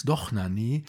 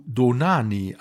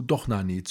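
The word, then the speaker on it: Dohnányi: Der Akzent auf dem A in Donani hat übrigens nichts mit der Betonung zu tun, sondern besagt nur, so ist das im Ungarischen, dass das A offen und lang ausgesprochen wird, also A und nicht O.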